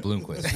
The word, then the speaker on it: Blomquist: I said Bloomquist. It's spelled wrong. retribution here. It's re- spelled wrong.